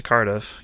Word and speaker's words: Cardiff: Did I say that right? Cardiff? – Yes, you did.